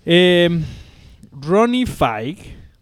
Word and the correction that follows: Fieg: que se escribe se escribe F y E G, que se pronunciaría Ronnie Fieg.